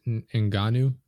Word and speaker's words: Ngannou: But Francis Ngannou, I think I'm saying that right.